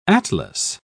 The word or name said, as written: atlas